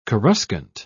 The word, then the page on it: coruscant: British English